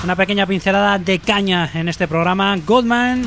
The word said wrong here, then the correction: Goddamn: a lo mejor pronunciado, a ver si lo digo bien, Goddamn, nos llegan desde tierras jienenses, fundados en 2009.